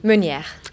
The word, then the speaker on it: Meneer: It is Jess Meneer. Not Afrikaans, French. So how do you pronounce it? – Meneer.